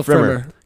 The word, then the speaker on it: Pfrimmer: Kendall Pfrimmer.